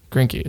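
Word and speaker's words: Greinke: But then Grinky is how you pronounce it.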